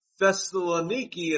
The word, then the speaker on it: Thessaloniki: He said, it's my great pleasure to say to you, to the church in Thessalonica, greetings, because Thessaloniki is actually the right way to pronounce the modern name of this.